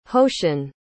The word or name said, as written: hoeshin